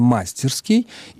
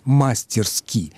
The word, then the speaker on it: мастерски: Да, «мастерски» действительно образовано от прилагательного «мастерский» или «мастерской», но оно-то образовано от существительного «мастер», и поэтому нашей слушательнице кажется, что правильно «мастерский» и «мастерски». Это старая и очень распространенная ошибка. Литературная норма требует, чтобы в наречии «мастерски» ударение стояло на суффиксе на последнем гласном.